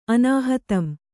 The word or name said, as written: ಅನಾಹತಂ